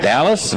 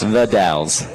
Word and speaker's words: The Dalles: It looks like Dallas or Dalles, but it's the Dalles.